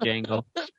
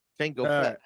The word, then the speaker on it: Django: Yeah, he's been free for quite some time, that Django. Django! – I know, not him. – It's with a D. The D is silent.